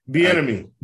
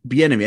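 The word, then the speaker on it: Bieniemy: I mean, God, I mean, you talk about, I'm thinking, you know, where's Eric, you know, what, how do you say his last name? Bonami or whatever. He interviewed, you know, Chiefs office. – Bienemy. – Bienemy.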